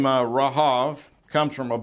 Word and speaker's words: Rahab: and other times spelled C-H-A-N-U-K-K-A-H. Rahab the harlot of Jericho fame is spelled with the hard H. And in Hebrew, her name is pronounced Rahav, or Rahab in English.